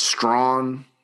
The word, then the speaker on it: Strachan: It's Strachan is how you – it's spelled, but it's Strong, Jordan Strong from Georgia State.